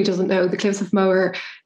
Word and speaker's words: Moher: Did I say that right? Moher? – Moher. Yeah.